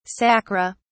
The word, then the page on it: sacra: British English